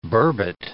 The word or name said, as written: burbot